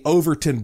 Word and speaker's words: Overtoun: Actually, it's called the Overton Bridge. That's spelled O-V-E-R-T-O-U-N.